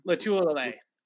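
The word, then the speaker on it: Lotulelei: Yeah, I mean, how do you pronounce his name? – Latulele. – There you go.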